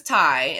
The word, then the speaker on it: Tai: Well, his name is Ty, and it's spelled T-A-I, because he's fancy.